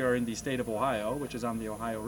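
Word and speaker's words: Ohio (おはよう): But it has one more syllable because Japanese syllabize everything. It's Ohio in Japanese.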